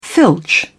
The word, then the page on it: filch: British English